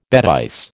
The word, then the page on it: bêtise: American English